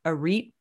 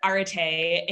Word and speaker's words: ARIT: And then you had the ARIT project. Do I have that right? – So it was actually pronounced Arite.